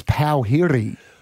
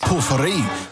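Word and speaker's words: pōwhiri: It is spelt P-O-W-H-I-R-I. – H-R-I. – So you would read it as Pauhiri. – Ah. Oh. – But it's pronounced... – Puffery.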